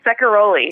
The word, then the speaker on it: Ceccaroli: Yeah, Ceccaroli. – Ceccaroli. Okay, so there's a hard C in there.